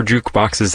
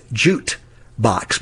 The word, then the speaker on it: jukebox: And I also call it jute box because a lot of people think that's the word.